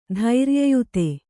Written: ಧೈರ್ಯಯುತ

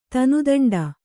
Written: ತನುದಂಡ

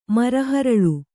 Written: ಮರಹರಳು

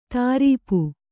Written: ದುರಾಪ

